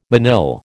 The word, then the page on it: manille: American English